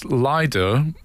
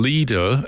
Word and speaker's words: Lido: And they say, Lido, as Lido, L-Y-D-O, is the southern pronunciation, Lido, as in Lido, L-E-E-D-O, is northern, simple but unimportant, they add.